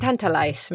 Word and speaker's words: tantalize: ¿Se escribe tintelize? – Tantalize, me parece. Con A. Con A, sí, tantalize.